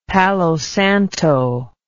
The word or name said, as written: palo santo